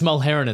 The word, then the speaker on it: Mulheran: It's Mulheran.